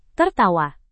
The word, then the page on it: tertawa: Indonesian